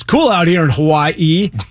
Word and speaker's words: Hawaii: So apparently, the correction is that when the W is in the word itself, it becomes pronounced with a V. Right? Like, Hawaii is Hawaii is the correct pronunciation.